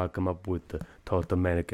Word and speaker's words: Ptolemaic: And that's a Ptolemaic with a silent P.